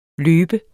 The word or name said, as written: løbe